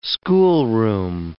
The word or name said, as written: schoolroom